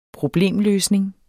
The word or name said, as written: problemløsning